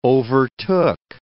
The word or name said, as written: overtook